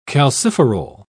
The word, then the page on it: calciferol: British English